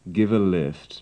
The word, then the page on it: give a lift: English